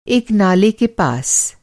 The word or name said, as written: नाला